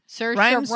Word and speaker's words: Saoirse: Saoirse Ronan. Rhymes with inertia.